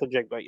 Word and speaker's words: Njigba: So you put an emphasis on the N, and then everything else is just pronounced the way it is. But Kanan Smith Njigba, yes.